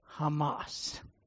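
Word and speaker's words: Hamas (חמס): But in Hebrew, if you know what the word for evil is, it is Hamas. That's how it's pronounced in Hebrew.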